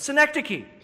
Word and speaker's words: synecdoche: But Gregory would counter by pointing out that these terms, flesh, soul, spirit, often when they're speaking of mankind, they're used as synecdoche. I hope I'm pronouncing, Aaron, am I pronouncing that right? Aaron's gone. Yes, I got the thumbs up. Synecdoche, that is declaring the whole by the part.